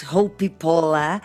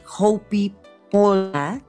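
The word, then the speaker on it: Hoppípolla: Y es una canción bien bonita, muy especial, que además esta banda islandesa, literalmente lo que es Hopi Pola, que se escribe Hopa y Polla, ¿no?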